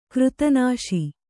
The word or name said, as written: ಕೃತನಾಶಿ